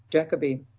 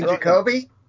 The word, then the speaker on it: Jacobi: And War Master 9, solitary, solitary Confinement, featuring, um, you know, the, the, the, the War Master as played by somebody whose name cannot be pronounced by Americans. Derek Jacobi. Derek Jacobi. Right then. you mean Jacoby?